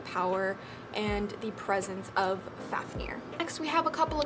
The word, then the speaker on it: Fafnir: Next, we have Fafnir, the Elemental Dragon Guardian of Fire, and this is spelled F-A-F-N-I-R, but is pronounced F-A-F-N-E-E-R, like near, not like Nair, because that's kind of how it's spelled, so we're gonna call him Fafnir instead of the way it's like spelled.